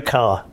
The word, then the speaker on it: Kerr: So my name is Joe Carr, but it's spelt K-E-R-R, and that's a Scots-Irish name, and I'm fiercely independent of you know, the Brits not mispronouncing a Scots-Irish name. But also, I get sick of being called Joker.